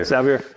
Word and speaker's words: Xaver: Xavier. – So X-A-V-E-R.